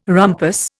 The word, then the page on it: rumpus: Indian